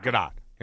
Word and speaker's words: Gadot: Well, I believe you pronounced the T. It's not French. – Gal Gadot? – Gadot.